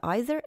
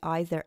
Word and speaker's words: either: Слово either произносится двумя разными вариантами. С долгим звуком I в начале – British English. Either. С долгим звуком «и» в начале. American English. Either.